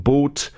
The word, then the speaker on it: Both: Original lineup featured Hans Tim Hinrichsen, Axel Storsberg, Björn Boat. That's been called, it's actually B-O-T-H, but I suppose you pronounce it boat.